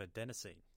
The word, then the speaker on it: adenosine: A lot of people don't realize that what caffeine is actually doing to their brain is there's a thing called adenosine in your brain. I hope I'm pronouncing that properly. – Yep.